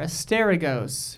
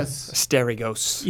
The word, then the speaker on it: Asterigos: It's got an extra syllable in there. – Yeah. – Like, get that I out of there. Asteragos. – Yeah. – Yeah. – Asteragos.